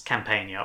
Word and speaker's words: Campania: Well, there's no silent G in this Campania or Campania. – Okay. – So take your filthy French pronunciation elsewhere. We're going with Italian, well, Latin pronunciations here.